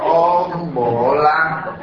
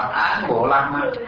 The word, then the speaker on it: Om Bồ Lam: Đọc là Om Bồ Lam, Om Bồ Lam. Giờ trên thường là đọc người ta thường đọc là Án Bồ Lam, nhưng mà đọc là Om Bồ Lam.